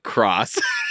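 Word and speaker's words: Xros: It's X-R-O-S, and you pronounce it Cross.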